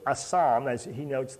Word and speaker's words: asham: A-S-H-A-M, asam. I pronounce it asam.